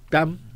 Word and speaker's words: 뺨: 빰이라고 하니까 더 아픈 것 같아요. 빰때는 빰. 음. 뺨. – 네, 네, 어, 발음 되시네요.